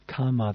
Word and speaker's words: kama: Kama, K long A-M-A, kama, that is sensual desires or the objects of sensual desires.